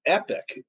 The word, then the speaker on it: Epoch: Epic, it's spelled EPOCH, but I think they spell it Epic.